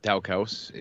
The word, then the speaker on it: Daukaus: Daukaus, if you'd like to pronounce it that way, which we do.